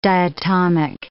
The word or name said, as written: diatomic